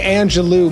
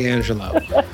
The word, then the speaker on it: Angelou: I've been saying Angelou my whole life, and it's actually Angelou.